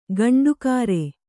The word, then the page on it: ಗಂಡುಗಾರೆ: gaṇḍu gāre